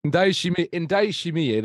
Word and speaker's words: Ndayishimiye: Yes, Ndayishimiye, that's it, yes, who they brought in from Istanbul, Başakşehir.